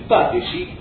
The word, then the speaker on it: Patesi: El Patesi, como suena, P-A-T-E-S-I, con acento en la A.